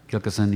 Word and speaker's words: Gilkyson: a, a, some incredible players, well-known players, Tony Gilson. Um, I'm pronouncing that correctly. Guitar. – Gilkyson, yeah. – Gilkyson, yeah. Uh,